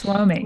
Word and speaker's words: WOMI: And it made me realize that I'm not sure how you envision it being pronounced, but I pronounce it when I read it, WOMI. – Yes, WOMI. Right? Correct. You got it.